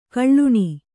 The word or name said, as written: ಗುಲ್ಲನೆ